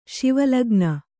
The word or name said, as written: ಶಿವಲಗ್ನ